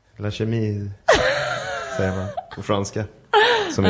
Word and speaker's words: Chemise: Jag kör en skjorta från La Chemie och ett par jeans från... – Uttalar man det så? – Nej, tror jag. – La Chemise. – Nej. – Skjorta på franska heter Chemise. Ja.